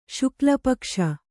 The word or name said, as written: ಶುಕ್ಲಪಕ್ಷ